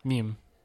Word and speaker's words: meme: The first E is a long E and the second one is silent. Meme. Right?